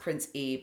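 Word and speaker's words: Prince Ea: It's pronounced Prince E, but it's spelled Prince E-A.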